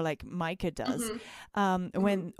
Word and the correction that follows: Mika: Or Mika, I don't know how you pronounce it. – Oh yeah, Mika.